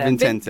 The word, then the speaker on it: Vincente: Yeah, her dad, Vincent. Is it Vincent or Vincente? – Vincente. Vincente, yeah. So we have a room full of Italians here, and I'm pronouncing it wrong.